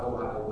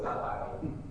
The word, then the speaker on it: Ahio: But Uzzah, or Uzzah, however you say his name, and Ahio, not Ohio, but Ahio, they're both also Abinadab's sons, Eleazar's brothers.